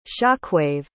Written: shockwave

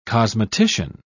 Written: cosmetician

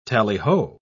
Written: tallyho

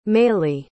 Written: meyly

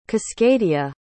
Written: cascadia

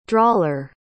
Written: drawler

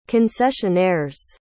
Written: concessionaires

